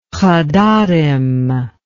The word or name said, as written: hadarim